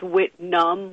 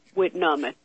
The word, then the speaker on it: Whitnum: It's Whitnum with an N-U-M. Num. Okay. Whitnum. Yeah.